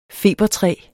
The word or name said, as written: eucalyptus